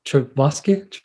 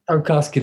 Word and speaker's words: Tarkovsky: Tarkovsky. – Tarkovsky, there we go.